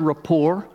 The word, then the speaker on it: rapport: So the word rapport I guess because it's from French or something, you don't pronounce the T at the end.